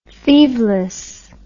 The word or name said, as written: thieveless